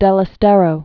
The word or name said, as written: Santiago del Estero